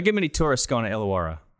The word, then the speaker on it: Illawarra: But, you know, and then this past season, he spent the year with, how do I say it now, Illawarra? – Illawarra Hawks, yeah.